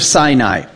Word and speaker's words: Sinai: God's speaking to the people from Mount Sinai for all to hear. So everybody hears his voice as he gives the Ten Commandments. Now, the interesting thing is the word Sinai, Mount Sinai, wilderness of Sinai.